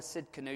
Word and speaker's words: Tsidkenu: The T is silent. Jehovah Tsidkenu.